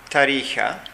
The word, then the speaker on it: Tarija: I was based in a town in the south called Tarija. – Oh, spell that. – It's T-A-R-I-J-A.